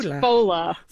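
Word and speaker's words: Fódla: It's more Irish. Fudla. It's Fola.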